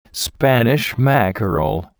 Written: Spanish mackerel

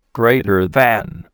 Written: greater than

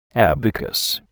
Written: abacus

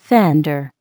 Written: fender

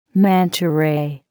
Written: manta ray

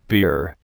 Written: beer

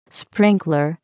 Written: sprinkler

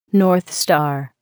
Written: North Star

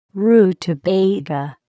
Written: rutabaga

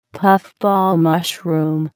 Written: puff ball mushroom

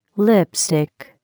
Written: lipstick

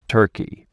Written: turkey

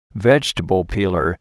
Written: vegetable peeler

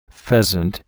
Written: pheasant

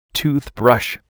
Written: toothbrush

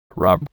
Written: broccoli raab

